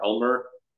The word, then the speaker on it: Elmur: So Victor_ Elmer. It sounds like the glue, but it's not spelled like the glue. It's ELMURR.